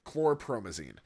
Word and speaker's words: chlorpromazine: He invited him inside, and noticing Wallace's acute state of tenseness and depression, volunteered to inject him with chlorpromazine, with the explanation that this would calm down and relax him.